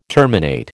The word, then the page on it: terminate: American English